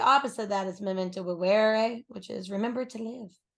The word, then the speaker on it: vivere: Vivere. – I would slur the shit out of that. – It's V-I-V-E-R-E, but V's in Latin are pronounced with W's.